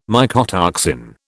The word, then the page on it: mycotoxin: British English